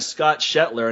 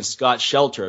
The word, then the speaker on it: Shetler: No, but we have our friend Scott Shetler, and hey, I got your name right this time. Last time I kept on saying Scott Shelter.